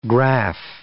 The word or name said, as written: graph